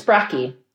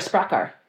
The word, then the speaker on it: Spraki: Yeah, the singular is Spraki. – Spraki. Okay. – Yeah, but together, I feel like we can say we are Spraka.